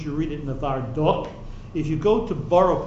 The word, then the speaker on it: Novardok: Before he was bebrois, he was born in bebrois, and the Aruch HaShulchan was rov in his city, spelled N-O-V-A-R-D-O-K. Will somebody please pronounce that for me? – Nevardok. – Oh, you got it right. Shani bo ke'es Nevardok.